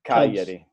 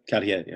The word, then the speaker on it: Cagliari: How do you say the name of that team, in Italian? – Cagliari. – Cagliari. All right.